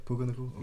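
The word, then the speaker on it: Puka Nacua: Puka Nakua? – Okay.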